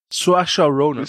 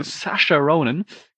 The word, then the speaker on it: Saoirse: Und, oh Gott, wie wird die denn nochmal ausgesprochen? Die Ronan. – Saoirse Ronan. – Saoirse Ronan. Genau, bekannt aus Lady Bird.